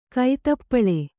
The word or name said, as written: ಕೈತಪ್ಪಳೆ